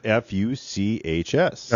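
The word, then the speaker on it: Fuchs: Well, it's F-U-C-H-S. – Uh-huh. Just one more letter and then German pronunciation.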